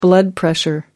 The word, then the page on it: blood pressure: American English